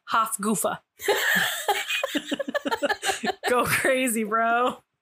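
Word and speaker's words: Hafgufa: It's G-U-F-A, not G-O-O, like goofy. – Okay. I did a strong U, goofa. Hufgufa. – It'd be like guffa.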